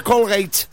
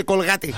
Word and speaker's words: Colgate: Estamos en Chile, aquí en Chile se lee como en Chile. No se dice Colgate, se dice Colgate.